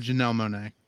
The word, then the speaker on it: Monáe: It's Janelle Monáe. – Monáe. – At least that's how I would pronounce it. It could be wrong, but that sounds right.